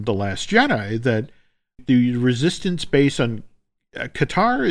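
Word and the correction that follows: D'Qar: is, am I saying that right? – Dakar. – Yes.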